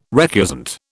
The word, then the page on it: recusant: British English